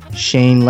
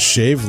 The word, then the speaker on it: Shane: Then we have Shane Legg, who reached out to me. – Oh, they said Shave Legg. – Not Shave. – Shane. – Shane. Shane Legg. I said it right.